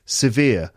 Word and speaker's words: severe: Severe. Two syllables. Vir, the second syllable, is the stressed one.